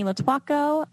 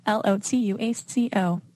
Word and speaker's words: Lotuaco: I'm on Instagram at Sydney Lotuaco, Lotuaco. So people always struggle with that's how you say it. Yeah. We totally said it wrong, I think.